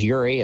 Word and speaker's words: Eury: My last name is Uri. It's spelled E-U-R-Y.